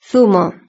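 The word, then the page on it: zumo: Spanish (Spain)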